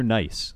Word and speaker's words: gneiss: Maybe you want to test that yourself, not get a young child to step over really rough, you know, quartz or n- gneiss. Nice or nice? I, this is a bit of a pronunciation quandary. – It is, and I am European, um, so we definitely say Nice, and okay.